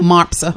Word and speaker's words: Mopser: A holiday choice winner of the 6th MOSPER. How do you say MOSPER? – MOPSER. – MOPSER. See, I think you said MOPSER.